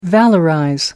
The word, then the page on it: valorize: American English